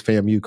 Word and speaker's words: FAMU: And I want to make sure I'm saying it right. So pretty much that's Florida A&M, it was FAMU, correct? Okay. – That's right.